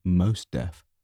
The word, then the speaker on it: Mos Def: I remember it just because it had Mos Def in it, and I really like Mos Def, so fucking, I'll give it a go. – Mos Def? – Mascanada. – I mean, Mos Def.